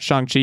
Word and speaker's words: Shang-Chi: Shang-Chi is what we're talking about.